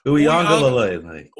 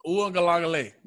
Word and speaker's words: Uiagalelei: Oh, it's Uiagalelei. – Uiagalelei.